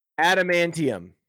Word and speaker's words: adamantium: How do you pronounce that? Wolverine. Bone material. Adamantium. Adamantium. It's adamantium. It's adamantium.